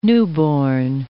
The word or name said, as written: newborn